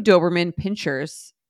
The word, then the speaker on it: Doberman Pinschers: To add to my anxiety that's already starting to build surrounding figuring out the logistics of our move, we have 2 Doberman Pinschers. Is that how you say it? – Yes.